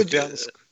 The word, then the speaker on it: Купянск: Купинск, ну, еще... Купянск.